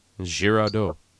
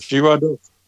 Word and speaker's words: Girardot: But you know, if you, okay, so it's a French last name, the T is silent, like Merlot or Brigitte Bardot. – Girardot. – There you go. – Yeah, guys. – Girardot.